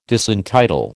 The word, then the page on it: disentitle: American English